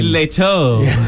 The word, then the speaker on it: Jared Leto: Jared Leto.